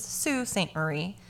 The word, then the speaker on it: Sault Ste. Marie: It is not Sault Ste. Marie. It is Sault Ste. Marie.